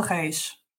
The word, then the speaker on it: Reis: And I pronounce it Reese. How do you pronounce it in Portuguese? Because I think it needs to be heard properly. – Okay, I'll say her full time. So it's Inspector Isabel Reis.